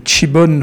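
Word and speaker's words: Šipon: Alors on parle d'un vin qui s'appelle le, le, Chibon. Vous avez entendu euh, l'appellation de ce vin. Alors pourquoi Ça s'écrit Sibon, hein. S-I-B-O-N, et on il explique dans cette euh, on l'explique dans cette petite dans cette courte interview.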